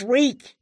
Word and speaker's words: Reek: My name is Reek." – He had to remember his name. – "It rhymes with freak."